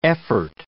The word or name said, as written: effort